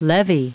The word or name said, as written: levee